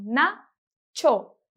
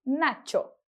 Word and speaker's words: nacho: A palavra nacho, que é aquela comida mexicana bem deliciosa, a gente pronuncia como nacho. Mais uma vez, nacho.